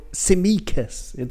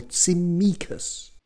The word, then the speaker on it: Tsimikas: Kostas will do, or Tsimikas. The second syllable, Tsimikas.